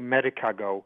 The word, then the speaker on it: Medicago: It's actually Medicago. – Medicago. – And it's Latin for alfalfa.